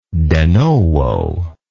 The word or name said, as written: de novo